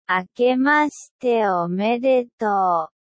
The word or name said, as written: akemashite omedeto